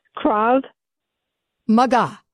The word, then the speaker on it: Krav Maga: Say it with me. Krav. – Krav. – Maga. – Krav Maga. Krav Maga. – That's it.